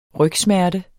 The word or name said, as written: rygsmerte